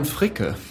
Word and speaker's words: Fricke: Moving on to Sounds 81, the wonderful Sandy Robertson interviewing Purple View's Florian Frick, you'd be able to pronounce that better. – Florian Fricke.